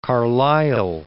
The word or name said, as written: Carlyle